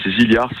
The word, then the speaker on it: Zigliara: C'est Zilliard, c'est un petit village au-dessus d'Ajaccio. Mm-hmm. Donc ça s'écrit Zigliara.